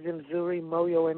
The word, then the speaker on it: Mzuri Moho: My name is Mzuri Moho Mbaye.